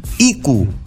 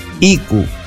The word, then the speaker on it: IQ: No, conocíamos a esta banda que vamos a presentar ahora, que es IQ. IQ. Y se escribe así: I latina C U. Así de simple.